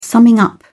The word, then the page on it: summing up: British English